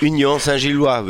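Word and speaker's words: Union Saint-Gilloise: Union Saint-Gillois, würde ich sagen.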